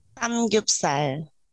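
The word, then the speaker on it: Samgyupsal (삼겹살): Ano yung tamang pronunciation ng ano, sangyup? – Samgyupsal.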